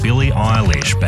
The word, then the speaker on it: Billie Eilish: Billie Eilish, Bad Guy.